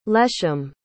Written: lesham

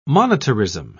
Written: monetarism

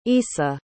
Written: isa

